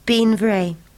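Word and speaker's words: Beinn Bhreagh: to which she gave the Gaelic name Bean Bray, pronounced Bean Vray, meaning beautiful mountain.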